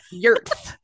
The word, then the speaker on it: Jord: It is J-O-R-D, but it's pronounced Yurt.